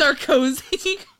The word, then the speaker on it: Sarkozy: Sardozy. – Oh, there you go. Did you say Sar- Sardozy? – How, how do you pronounce it? – Sarkozy.